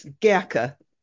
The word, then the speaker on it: Gerke: Now, to begin with, what led you to the Grech family? Now, check my pronunciation in German. Is that the correct pronunciation? – No, it's a difficult word. It's Gerke. – Gerke, okay. The Gerke family story.